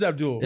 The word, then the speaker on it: Abdel: Is it Abdel or Abdul? – Abdul. – It is Abdul. – It's spelled Abdel, okay, Abdul. But it's pronounced Abdul.